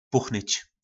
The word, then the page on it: Puchnyc: Po našimu